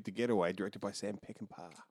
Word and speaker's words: Peckinpah: It's Peckinpah.